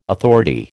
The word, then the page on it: authority: American English